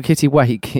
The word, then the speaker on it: kittiwake: Or a kittiwank seagull. – Kittiwake, yes.